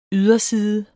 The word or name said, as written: yderside